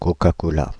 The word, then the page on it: Coca-Cola: France (Île-de-France); [ko.ka.ko.la]